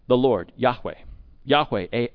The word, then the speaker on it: Yahweh: And so the question is, well, how do you pronounce God's name? Some would say Jehovah, but most scholars today believe that the proper pronunciation of this YHWH is Yahweh.